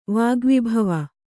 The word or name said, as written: ವಾಗ್ವಿಭವ